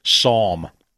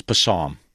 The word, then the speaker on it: Psalm: And it's spelled with a P. – Right. – Yeah. I would go around as an adult and say, no, it's Passam.